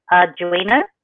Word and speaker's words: Arduino: Arduino.